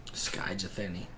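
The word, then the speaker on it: Skye Jethani: Sky Jathani. You pronounced that very well.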